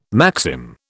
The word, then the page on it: maxim: British English